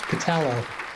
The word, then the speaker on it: Pitalo: Very close. It's Pitalo.